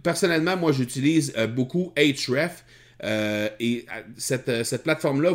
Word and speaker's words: Ahrefs: La deuxième astuce que je veux vous servir, c'est d'utiliser euh, Buzz Sumo ou encore SEM Rush ou encore HREF. HREF, là, ça s'écrit A-H-R-E-F-S.